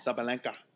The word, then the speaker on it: Sabalenka: Sabalenka. – That one's pretty much self-explanatory. It's spelled the way it's said.